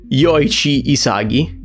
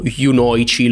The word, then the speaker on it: Yoichi Isagi: Yoichi Isagi, Isagi, questa cosa io me lo ricordo sempre. A volte l'ho chiamato Yunoichi, l'ho chiamato Inoichi, l'ho chiamato tipo Isochi, cioè ogni volta lo pronuncio in modo diverso.